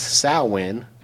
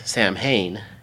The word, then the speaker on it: Samhain: And on this special day, which is pronounced Samhain, spelled, if you were to look at it, looks like Samhain, they would be released.